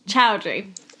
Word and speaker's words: Choudhury: Like Choudhury. Chow. – Okay. – And then Dree. – Choudhury.